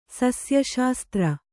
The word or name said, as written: ಸಸ್ಯಶಾಸ್ತ್ರ